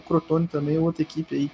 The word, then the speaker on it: Crotone: ficar de olho para trabalhar pronto é o Cotrone, né? Aí eu falei errado. Crotone.